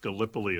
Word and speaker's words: Gallipoli: And what it was is it's actually, I think, pronounced Gallipoli. It's a place in Turkey.